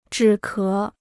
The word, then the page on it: 止咳: zhǐ ké